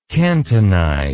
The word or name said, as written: cantonization